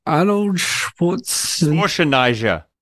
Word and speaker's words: Arnold Schwarzenegger: Arnold Schwarzenegger.